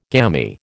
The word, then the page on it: gammy: American English